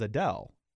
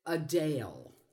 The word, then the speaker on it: Adele: but I always hear it as Adele.